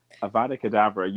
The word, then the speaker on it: Avada Kedavra: Avada Kedavra, I keep saying it wrong. Avada Kedavra, you know for sure, like, okay, I'm dead.